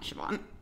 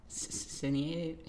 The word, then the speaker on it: Sinead: I know it's not that. Her name's not Siobhan. It's Sinead. – Sinead. – But it's spelled S-I-N-E-A-D. Like Sinead O'Connor.